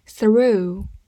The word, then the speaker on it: through: r o u r u ru，o u 字母组合发 u，g h 不发音。 through。